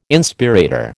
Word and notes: Inspirator: American English

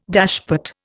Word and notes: dashpot: Indian

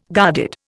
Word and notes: guarded: Indian

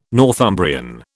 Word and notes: Northumbrian: British English

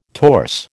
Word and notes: torse: American English

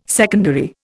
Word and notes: secondary: Indian

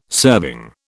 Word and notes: serving: British English

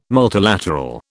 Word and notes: multilateral: British English

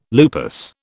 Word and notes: lupus: British English

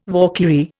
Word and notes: Valkyrie: Indian

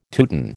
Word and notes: Teuton: American English

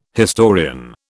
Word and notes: historian: British English

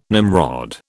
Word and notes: Nimrod: British English

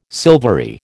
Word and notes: silvery: American English

